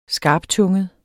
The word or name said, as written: skarptunget